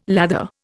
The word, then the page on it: lather: Indian